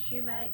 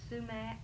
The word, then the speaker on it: sumac: Anything called, people pronounce it different ways. Some people call it shumate, some people call it sumac, some people call it...